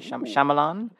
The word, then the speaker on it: Shyamalan: Shyamalan. – M. Night Shyamalan. Put some respect on that gentleman. – Yeah, sometimes my brain just wants to say it how it's spelled, and that is wrong.